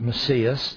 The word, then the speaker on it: Messias: There is a Greek word, Messias, and they pronounce it Messias.